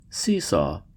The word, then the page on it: seesaw: American English